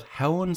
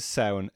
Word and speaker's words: Samhain: Like, some people call it Howan, some people call it Samhain. – I'm a fluent Irish speaker, right? The word is Samhain, S-A-M-H-A-I-N. Which refers to autumn.